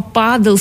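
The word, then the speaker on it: pádel: Так вот, дело в том, что испанский язык адаптировал английское слово падл под свои фонетические правила. И так появилось слово «падл» с ударением на «а» – официальное название нового вида спорта.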